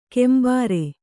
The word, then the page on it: ಕೆಂಬರೆ: kembare